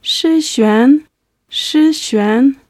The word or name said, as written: shixuan